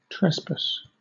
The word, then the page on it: trespass: British English